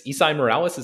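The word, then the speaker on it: Esai Morales: Okay, so yeah, the Gabriel character, I think his name is Isai Morales. Is that how you pronounce it? – I believe so. – I think that's right.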